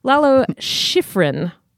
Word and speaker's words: Schifrin: The Lalo Schifrin. – What that now? – Lalo Schifrin composed the original. I'm sorry, I'm saying this wrong. Lalo Schifrin composed the original Mission Impossible theme.